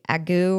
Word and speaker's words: ague: And A-G-U-E, agu, or I'm not quite sure how to say it either, is a fever.